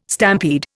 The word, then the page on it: stampede: Indian